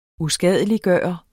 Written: USKADELIGGJORTE

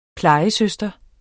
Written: plejesøster

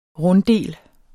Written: runddel

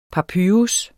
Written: papyrus

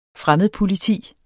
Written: fremmedpoliti

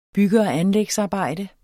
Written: anlægsarbejde

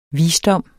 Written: visdom

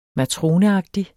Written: MATRONEAGTIG

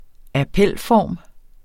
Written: appelform

